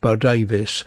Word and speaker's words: Bodawes: So the name to remember, Janet Bo Davis, and the Janet is spelt with two N's, and Bo Davis is actually spelt Bodawes, but it's pronounced Bo Davis.